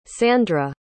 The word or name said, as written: sandra